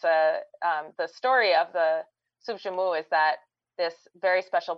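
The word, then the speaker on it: soup joumou: Yeah, soup jumu is the national dish of Haiti.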